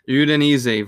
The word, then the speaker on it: Udinese: Udinese versus Inter Milan. – Yes, so Udinese versus Inter Milan.